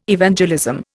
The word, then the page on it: Evangelism: Indian